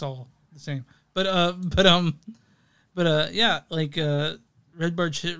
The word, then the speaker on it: Barchetta: Yeah, because what I'm seeing is the second syllable should be covered with a hard K instead of a soft ch. So Barchetta.